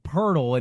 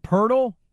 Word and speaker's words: Poeltl: It just, yeah, it's a bit of an ugly American thing to say out loud, but it always throws me off because I say it, Jakob Poeltl, and then you see it, and doesn't sound how it's spelled for us.